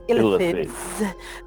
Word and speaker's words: Illithids: held in captivity for generations by the Illithids. – The dwarves eventually won their independence with the Il- Illith- Illithids. – Mm. Illicit. – You said it right the first time, and I interrupted.